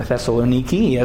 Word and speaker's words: Thessaloniki: all the brothers and sisters in Thessalonica, or Thessaloniki, as it is now pronounced in how it's known in Greece today.